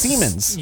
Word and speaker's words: Siemens: And it sounds like the word semen. – Yes. There's also some... – I thought you would really attach on to that. Just Siemens.